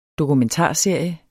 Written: dokumentarserie

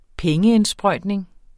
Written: pengeindsprøjtning

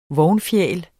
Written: vognfjæl

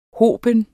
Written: hoben

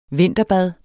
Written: vinterbad

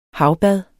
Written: havbad

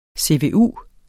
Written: CVU

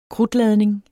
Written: krudtladning

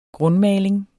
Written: grundmaling

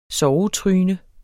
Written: SOVETRYNERNES

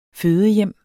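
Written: fødehjem

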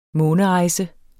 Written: månerejse

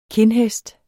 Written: KINDHESTS

